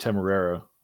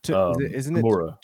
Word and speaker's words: Temuera: My Twitter complaint has been that I agree with Temerara. I think I'm saying his name right. If I'm not, I apologize. – Temuera.